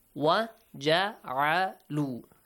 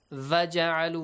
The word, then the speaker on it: وَجَعَلُوا: Make sure you don't say va. Va ja'alu, we don't have. In Persian, you might say va. Yes? – Yes. – In English, you might say v. You might have this sound with the letter v. But in Arabic, you don't have it. Wa.